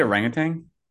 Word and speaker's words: orangutan: Everyone's thinking there's a G at the end. There's no G at the end of orangutans. It's wild because you pronounce the G.